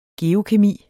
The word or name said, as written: geokemi